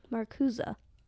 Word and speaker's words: Marcuse: We come forward now to Herbert Marcuse, which is spelled like Marcuse. Okay. But I listened to also a bunch of fancy people saying Marcuse. – Marcuse.